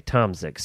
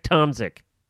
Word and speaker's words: Tomczyk: Tomczyk. – So you don't pronounce the C at all. – Okay. Tomczyk. So, it's Lisa Bick Tomczyk.